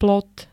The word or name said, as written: plot